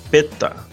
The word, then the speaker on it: PETA: Uh, acho que é a PETA. Acho que é assim que se pronuncia.